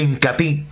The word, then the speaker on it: Incatea: Se escribe Incatea, pero se pronuncia Incatí.